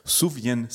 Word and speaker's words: Sufjan: Mein Kandidat für das Album des Jahres 2021 ist ein Singer-Songwriter aus den USA mit dem etwas ungewöhnlichen Namen Savian Stevens. Ich hoffe, das Savian spricht man richtig aus. Suvian Stevens.